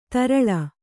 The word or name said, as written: ತೆರಳಿ